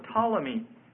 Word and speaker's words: Ptolemy: That's spelled S-E-L-E-U-C-U-S. Seleucus. And the second was Ptolemy. P-T-O-L-E-M-Y, Ptolemy.